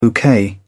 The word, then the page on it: bouquet: British English